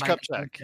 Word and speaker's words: Kupchak: I Mitch Kupchak, yes.